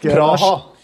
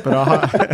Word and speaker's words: Praha: Åssen uttaler man det på, på norsk? – Praha. Praha.